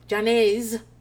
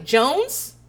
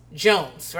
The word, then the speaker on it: Jones: When my last name was Jones, I've had people go, so Miss Janae's. I was like, Jones? Jones, right? And they're like, oh, is that how you say it? I thought it was one of those foreign names.